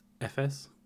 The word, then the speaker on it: Efes: I believe it's pronounced Efes. Okay. Efs. It's a Turkish pilsner.